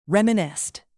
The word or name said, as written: reminisced